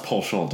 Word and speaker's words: penchant: It's pronounced penchant, darling.